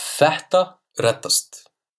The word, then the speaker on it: Þetta reddast: ÞEtta Reddast. – ÞEtta Reddast.